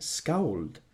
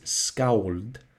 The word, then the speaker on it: skald: Snorri's upbringing, training, and ability in oral tradition made him an excellent skald, or professional poet. Now, the pronunciation in modern Icelandic is skald, but I'll probably just anglicize it to skald, as English is my native language.